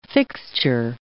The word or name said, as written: fixture